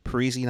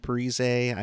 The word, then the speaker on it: Parisi: Uh, that's that's how he pronounces it right now this week, right? Parisi, not Parise.